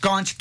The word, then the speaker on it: gaunch: So ginch, gaunch, gitch, gotch, and they all have to be pronounced with a hard G.